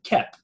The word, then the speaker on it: Koepp: Like, if you've seen the director's name written down, his surname is spelled K-O-E-P-P. Now, I didn't know until doing prep for this show that his surname is German and is pronounced Kep, so you'd spell it K-E-P. So there you go. – Not Coep.